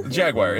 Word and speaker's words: Jaguar: I pronounce it like the car. Jaguar.